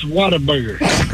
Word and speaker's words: Whataburger: Well, on that Whataburger, it's pronounced Whataburger.